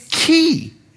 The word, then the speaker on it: cay: I know it's spelled C-O-N-C-H, but it's conch chowder. And by the way, it's not K. It's key. It's not Green Turtle K, it's Green Turtle Key. I know it's spelled C-A-Y, but it's key. And if you're from the Bahamas, you'll know that you don't say K, you say key.